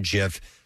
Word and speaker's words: GIF: But he, all I know is he said that he pronounces it with a soft G. He described it, he declared that pronounced with a soft G, like peanut butter brand GIF.